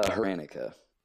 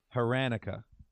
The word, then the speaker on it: Hranica: Uh, Heranica. Heranica.